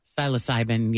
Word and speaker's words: psilocybin: He was also arrested back in 2020 for possession of several drugs, including marijuana, MDMA, and psilocybin. Is that how you pronounce it? – Psilocybin, yeah.